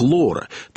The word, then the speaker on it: Laura: Имя Лаура по-английски читается как «Лора», то есть это видоизмененная Лара, героиня доктора Живаго.